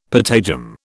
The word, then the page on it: patagium: British English